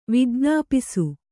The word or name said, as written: ವಿಜ್ಞಾಪಿಸು